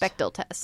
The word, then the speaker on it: Bechdel: And also, I believe I'm pronouncing this right, Bechdel test? – Bechdel test, ooh, yes.